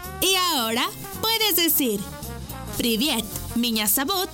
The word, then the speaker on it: Меня зовут: Y se escribe P-R-I-V-I-E-T. Привет. ¡Muy bien! Repite conmigo. Привет. ¡Muy bien! En ruso, me llamo se dice Меня зовут. ¿Y tu nombre? Una vez más. Меня зовут. Por ejemplo, Меня зовут. Esta palabra se escribe Меня. M-I-Ñ-A. Меня зовут. Z-A-V.